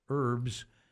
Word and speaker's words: herbs: Always raving about the fresh herbs. – Yeah. – It looks like herbs to me, but I read, I guess that's kind of from the French. They don't pronounce the H.